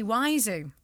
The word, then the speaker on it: Wiseau: Tommy Wiseau. – Tommy Wiseau. – I can never pronounce names. It's spelled Wiseau. – Oiseau. Oiseau. That's French for bird.